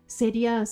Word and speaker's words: Seria: Seria se escribe S-E-R-I-A, o sea, literalmente se escribe Seria, de soy seria, eres serio.